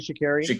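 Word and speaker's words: Sha'Carri: Shikari, yep.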